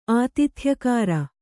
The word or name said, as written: ಆತಿಥ್ಯಕಾರ